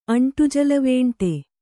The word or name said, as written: ಅಂಟುಜಲವೇಂಟೆ